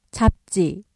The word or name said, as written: 잡지